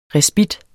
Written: RESPITTEN

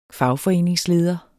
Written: fagforeningsleder

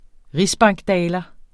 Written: rigsbankdaler

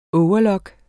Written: overlock

